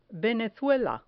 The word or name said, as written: venezuela